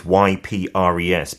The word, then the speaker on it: Ypres: And then if you go to, we talked about it, the spelling is Y P R E S, but you pronounce it Ypres.